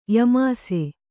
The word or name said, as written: ಯಮಾಸೆ